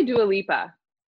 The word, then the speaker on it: Dua Lipa: And you also had, I feel like I always say this young lady's name wrong, Dua Lipa? – I say Dua Lipa. – Lipa, okay. – Dua Lipa, yeah.